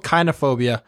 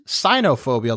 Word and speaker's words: kynophobia: Fun fact, when I was compiling the list, it's called kynophobia. I originally pronounced it as cynophobia, like cyanide, but it turns out cynophobia is the racial hatred of Chinese people.